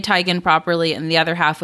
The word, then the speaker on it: Teigen: Really, her name is pronounced Teigen. – Is it really? – Yeah, her name is Chrissy Teigen. – Why doesn't she correct people? – Because everyone says Teigen, and so she said it's like too late.